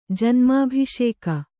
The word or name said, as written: ಜನ್ಮಾಭಿಷೇಕ